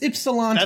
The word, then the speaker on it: Ypsilanti: So Mark Preston of Yokohama, Michigan. – Ypsilanti, Michigan. – Yokohama. – I'm not pronouncing that. – It's not Yokohama. – That's like, it's Ypsilanti.